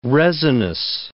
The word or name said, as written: resinous